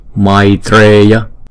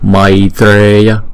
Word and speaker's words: maitreya: Câu này chúng ta đọc là maitreya, maitreya. Chữ này không phải là may, mà không phải là mai, mà là may, ai. Chữ A, Y là một nguyên âm ha.